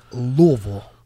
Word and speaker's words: Louisville: Nothing irks me more than people saying, oh, you went to Louisville. You went to Louisville. – Louisville. – And I asked them, what college did you go to? Yeah. Pronouncing it like that. – Yeah. – It is Louisville.